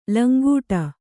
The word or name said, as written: ಲಂಗಡ